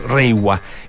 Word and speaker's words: Reiwa: En los signos que se usan en Japón, obviamente que no se escribe así, pero se pronuncia más o menos en esos términos, Reiwa.